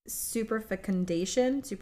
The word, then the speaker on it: superfecundation: Super fecundition. – Super fecundition. – That's probably wrong. – Super fecundation. It's spelled super fecundation.